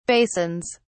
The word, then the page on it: basins: British English